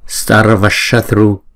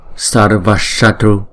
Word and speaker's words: sarvashatru: còn chữ S mà có dấu sắc trên đầu nè thì chúng ta sẽ ển cái lưỡi lên, không? Chúng ta để cái đầu lưỡi gần cái bên trong của cái hàm răng chúng ta đó, chúng ta ển cái lưỡi lên đọc là S S. Sarvashatru sarvashatru, không? Shatru. Chữ T đứng một mình chúng ta đọc là T.